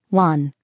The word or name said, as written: 1